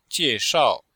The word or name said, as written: jie4shao4